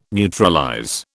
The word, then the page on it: neutralize: British English